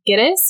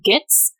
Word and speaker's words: Gittes: Another cool fact that we pulled from IMDb is that Cross, his mispronunciation of Gittes, he calls him Gitz.